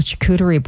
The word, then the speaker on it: charcuterie: It's also Charcuterie Board Day. Did I say that right? Some people say it differently. I think it's charcuterie.